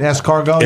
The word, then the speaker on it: escargot: Escargot? – Escargot.